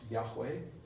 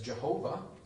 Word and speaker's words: Yahweh: So when you have the capital letters, L-O-R-D, that's referring to the covenant name of God. Probably pronounced Yahweh, mispronounced as Jehovah.